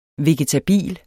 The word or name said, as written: VEGETABILET